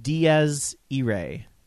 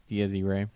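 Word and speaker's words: Dies Irae: By the way, Sia Tiberi would like you to know it's pronounced Diaz Irae. – Diaz Irae.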